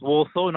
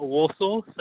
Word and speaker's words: Walsall: Yeah, it's pronounced Warsaw, not Warsaw. So yeah, okay.